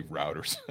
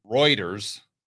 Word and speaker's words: Reuters: I would say routers. you can tell one of my first jobs was working on a construction site. Okay, we get over to Reuters. That's pronounced right.